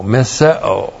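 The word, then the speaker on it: miseo (μισέω): M-I-S-E long O, miseo.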